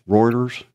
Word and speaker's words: Reuters: Now, I've got a report here from Reuters, I think is how you say it. – Right? – Yeah.